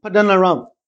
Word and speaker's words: Paddan Aram: Please, if you want to pronounce these things properly, you got to speak fast. It sounds like Boko Haram, isn't it? He says, go to Paddan Aram, to the house of Bethuel.